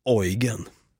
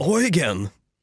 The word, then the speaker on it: Eugen: Nej, Eugen. – Eugen? – Eugen! Det låter som att man säger oj, again. Oj, again, Eugen.